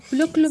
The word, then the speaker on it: Ptolomeo: Su papá se llama Plotlomeo. – ¿Cómo se llamaba? – Ptolomeo. Se escribe P-T-O. Ptolomeo.